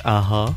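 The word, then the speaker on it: a-ha: They're from Norway, pronounced Aha.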